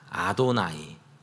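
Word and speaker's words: 아도나이 (אֲדֹנָי): 뭐라고 읽었냐면 아도나이라고 읽었습니다. 아도나이. 저렇게 써놓고 완전히 다른 이름인 아도나이라고 불렀는데 그게 바로 나의 주님이라는 뜻입니다.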